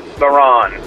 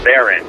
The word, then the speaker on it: Theron: It's Charlize Theron. Somebody give him the message so he can rant on me, because it drives me nuts. I must have called in 10 times about this. Not Theron, it's Theron.